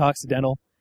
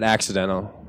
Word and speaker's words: Occidental: Uh, oh, at, at, some point I wanted to bring up how basically you guys uh, went to Occidental, and I and I, I wanted. To, am I saying that right? Okay, and uh, yeah, definitely not, not, not accidental. – No, uh, definitely not.